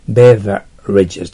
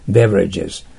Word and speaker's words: beverages: beverages と発音します。 v は下唇を噛んでください、少し。 beverages。で、 r は曖昧な発音してください。 beverages。